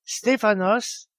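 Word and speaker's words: Stéphanos (Στέφανος): Il se prononce en grec Stéphanos et il signifie le couronné.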